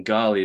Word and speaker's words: Ghali: Either you can go to LinkedIn and just search or any platform to Elliot with two L's, one T, and Ghali is spelled G H A L I.